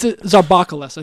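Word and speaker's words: Zambarloukos: I think you got it better the first time. – Okay, yeah, I'm butchering it there. It's Zarbakalis, I think. There we go. – That sounds better.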